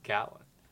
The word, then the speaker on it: Gallatin: Gatlin, uh, Grant, uh, which, hold on, they always say it, it's spelled Gallatin but I've always heard it pronounced Gatlin.